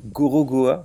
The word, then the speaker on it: Gorogoa: Est-ce que le jeu Gorogoa vous dit quelque chose Absolument pas. Absolument pas. Comme ça se prononce, G-O-R-O-G-O-A.